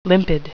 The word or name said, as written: limpid